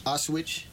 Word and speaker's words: Auschwitz: And this book that he wrote is chronicling his experiences as an Auschwitz. I'm sure I said that incorrectly, but... – Auschwitz.